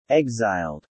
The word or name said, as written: exiled